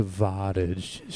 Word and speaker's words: Savatage: No, it's pronounced Savatage.